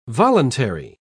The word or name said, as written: voluntary